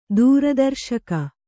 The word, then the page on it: ದೂರದರ್ಶಕ: dūra darśaka